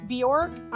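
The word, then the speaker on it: Björk: Bjork.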